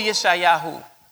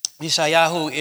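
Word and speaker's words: Yeshayahu (ישעיהו): Go to Yeshayahu, Isaiah. Yeshayahu is the pronunciation in the Hebrew.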